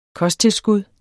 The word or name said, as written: KOSTTILSKUD